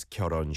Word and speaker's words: 결혼: Yeah, the two syllables are kyol and hon, but when it goes together, the hit is kind of weak, right? Kyoron sontek.